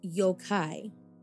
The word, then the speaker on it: yokai: Now, I can't remember if we dug into it, but the Japanese word for a monster is yokai. And I hope, again, I'm saying that right. – Yes, it is. You're saying it right because Monstrum talks a lot about yokai.